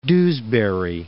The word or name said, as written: Dewsbury